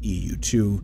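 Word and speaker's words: EU II: Again, it's EU and then the Roman numerals for 2. So EU, it looks like EUII, but obviously it's pronounced EU2 timeline.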